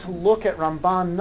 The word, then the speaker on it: Ramban: So the way to remember it is we say Rambam, Maimonides, with the emphasis on the first syllable, and Ramban, with Nachmanides, with the emphasis on the second syllable.